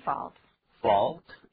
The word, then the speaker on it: fault: It's not my fault. – Fault, 也就是错误，这个字我们再把它念一下好吗？ – Fault, fault. 注意不要念作 fault, 不是 o 的音，是 r.